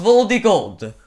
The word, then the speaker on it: Voldigord: So essentially, it's about a dude named Anus Voldigord, or as they pronounce it, um, Anus Voldigord. That's what it sounds like. Uh, so I'm, a, but I guess it's apparently pronounced V O L D I G O R D. So Anus Voldigord.